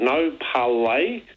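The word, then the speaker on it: No Parlez: The name of the album, I'm sure this has got a silent Z on the end. It's called No Parlay. – Yeah, No Parlay. No Parlay. – Silent Z, I'd imagine that would be. – Yep, that's it.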